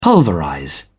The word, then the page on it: pulverize: British English